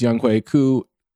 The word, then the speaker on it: Younghoe Koo: As a Korean American, I'm sure you know the proper way to pronounce his name is Younghoe Koo, or in Korean, Koo Younghoe.